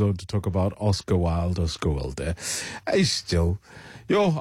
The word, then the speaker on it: Wilde: I was like, it's pronounced Wilder. I wasn't correct, but yeah, it was a lesson learned.